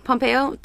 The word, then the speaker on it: Pompeo: Pompeo? – That she's gonna come back for an episode?